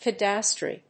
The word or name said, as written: cadastre